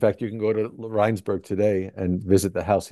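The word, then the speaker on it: Rijnsburg: Did he moved to Regensburg, right? – Reinsberg, yes. It's the Dutch pronunciation.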